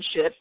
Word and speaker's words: Scheat: And we do, it is actually how it's said, S-C-H-E-A-T, said shit.